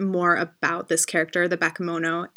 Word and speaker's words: Bakemono: I think it's Bakemono. It's a Japanese term for a type of supernatural creature.